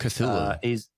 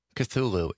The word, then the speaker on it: Cthulhu: Cthulhu. – What is it? – Cthulhu. It's a Cthulhu.